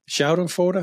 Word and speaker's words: Schadenfreude: Schadenfreude, have I pronounced yeah, it correct? Yeah, yeah what it's German, that? – basically yeah.